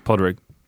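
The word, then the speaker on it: Pádraig: Alors, ça s'écrit pas Drake, mais c'est un prénom irlandais, donc ça se prononce peut-être Porrick, je sais pas, McKinley. – C'est Patrick, en fait. – Patrick, ouais. – Podrick.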